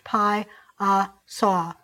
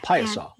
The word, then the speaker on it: Piasa: Alton, Illinois has the Piasso bird, P-I-A-S-A, and it's supposedly pronounced Pi-a-saw bird. – Yeah, Pi-a-saw.